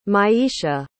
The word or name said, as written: maisha